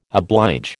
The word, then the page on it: oblige: American English